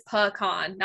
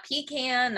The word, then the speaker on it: pecan: It is pecan, not pecan, not any other way. It is properly supposed to be pronounced pecan, because I did a blog post about this.